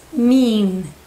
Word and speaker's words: mean: Y la última es mean. Con una I larga esta. Mean.